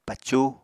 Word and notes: pacu: Apatani

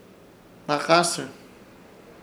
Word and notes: Hnakãsɨr: Ishir Chamacoco

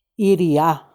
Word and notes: iria: Macuiltianguis

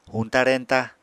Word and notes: huñta-reñta: apatani

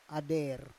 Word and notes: ader: Roon

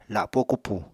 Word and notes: lapó kupù: apatani

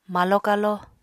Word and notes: malo-kalo: apatani

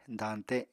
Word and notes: dañte: apatani

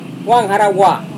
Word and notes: wangharawa: Serewen

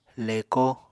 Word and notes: leko: Apatani

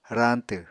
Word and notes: rañtw: Apatani

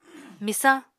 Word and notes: Misañ: Apatani

